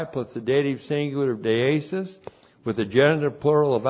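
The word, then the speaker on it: deesis (δέησις): And then we have deesis. Deesis looks like that in the Greek, D-E, long E-S-I-S, deesis.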